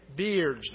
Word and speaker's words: Baird: David Baird's. We have to figure out how it's pronounced. David Baird's. Beards. David Beard's. All right. Just like it's spelt there.